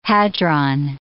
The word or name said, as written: hadron